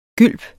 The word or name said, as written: gylp